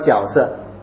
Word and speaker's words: 角色: 角色啊，每个人都角色。你去听，你注意听一下，是角色，角色。